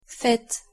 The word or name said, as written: faites